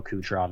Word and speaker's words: Kucherov: And yeah, you said it right about Kucherov.